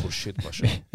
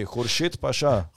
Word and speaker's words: Khurshid Pasha: And yes, that is how that's pronounced, Khurshid Pasha. – I was about to say. – That's his name. You can thank the Persians for that because Khurshid is a Persian name.